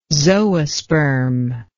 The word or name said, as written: zoosperm